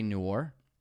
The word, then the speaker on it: noir: The Usual Suspects is such a great contemporary noir. I said it correctly, didn't I? – You said noir correct.